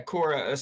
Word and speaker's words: Qora: Yeah, Quora. So it's spelled C or Q-O-R-A. A lot of people get it confused with Q-U-O-R-A, whatever that is, like some communications platform.